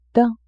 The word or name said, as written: daim